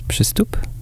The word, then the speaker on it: Przystup: The pronunciation is Przystup. It's a very difficult Polish name.